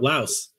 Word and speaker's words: Lao: But technically, the name of the country is Lao. But because, you know, the French, they always add like the silent S's at the end of things, even though they don't pronounce it.